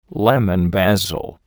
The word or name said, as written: lemon basil